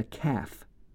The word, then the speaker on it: calf: A baby horse is a foal, F-O-A-L. How about a baby cow? What's that? A baby cow is a calf, C-A-L-F. Yes, the L is silent. We say calf.